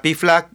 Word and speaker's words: PFLAG: Y si van a entrar a Piflag, fíjense que no diga .com, tiene que decir .org.